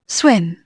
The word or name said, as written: swim